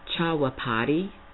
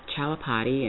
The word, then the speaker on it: Chaupadi: and I hope I'm pronouncing this correctly, Chawapati. Let me try that again. Chawapati, and it's spelled Chaupadi.